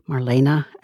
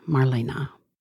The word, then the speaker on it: Marlene: And I'd like to add to those of you who might have noticed that Mr. Gortner used two different pronunciations of her name, Marlena as well as Marlene. According to his publicist, both ways are correct. However, Miss Dietrich's daughter, Maria Riva, refers to her as Marlena.